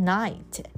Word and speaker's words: knight: It was a handsome knight on the beautiful white horse. 那是什么呢？是谁呢？是一个帅气的骑士。 A knight. 这个 K 不发音